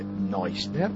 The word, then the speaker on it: Neusner: è scritto Neusner, il cui volume è stato tradotto in italiano l'anno scorso. Questo Neusner, Neusner, ma in tedesco si legge Neusner,